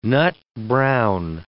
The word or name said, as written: nut-brown